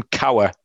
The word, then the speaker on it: Kowar: The pitching matchup is Jackson, right, how are we pronouncing this? Kowar? Yeah. Jackson, K-O-W-A-R. Yeah. Jackson Kowar. Kowar.